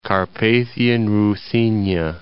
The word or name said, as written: Carpathian Ruthenia